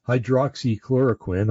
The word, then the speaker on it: hydroxychloroquine: Oh, about hydroxychloroquine. I'm going to say it right this time. Thank you to the sister that reminded me that I had said it wrong.